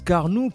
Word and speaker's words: Carnoux: Ça se prononce Carnoux.